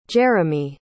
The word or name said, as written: jeremy